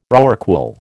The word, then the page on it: rorqual: American English